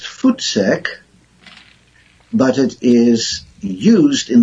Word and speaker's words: voetsek: And it's spelled V-O-E-T-S-E-K, but it's pronounced like your feet, like footsack? – Yes, because the V in Afrikaans is pronounced as an F. – Oh, okay. – So, footsack.